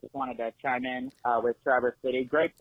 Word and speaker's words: Traverse City: I'm in with actually, just to clarify the pronunciation of the city, it's Traverse City, Michigan. – Traverse, not Traverse.